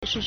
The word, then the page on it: 时事: shíshì